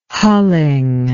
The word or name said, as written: hulling